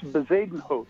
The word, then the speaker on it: Bezuidenhout: It's Bezuidenhout.